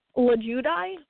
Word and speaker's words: Lulgjuraj: Lulgjuraj? – Lulgjuraj.